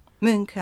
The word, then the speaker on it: Munch: Sylvia Plath gibi, Hemingway, Nietzsche, Van Gogh, Edward Munch. – Münk, aha, Münk diye Münk, telaffuz ediliyormuş.